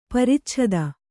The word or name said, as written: ಪರಿಚ್ಛೇದ